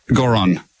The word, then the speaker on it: Goron: Goron, G-O-R-O-N. – G-O-R-N. – G-O-R-O-N. – G-O-R-O-N, okay, cool.